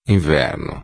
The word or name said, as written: inverno